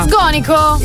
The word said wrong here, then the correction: Sgonico: In realtà l'accento è diverso, è Sgonico.